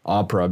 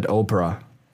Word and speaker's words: opera: Like my favorite is opera, basically. In the beginning, I always said Oprah. – Okay. – Because you would think by the pronunciation, like when you learn English, it could be Oprah.